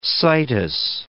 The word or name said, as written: situs